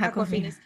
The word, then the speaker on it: Awkwafina: Awkwafina's.